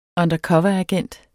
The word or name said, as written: undercoveragent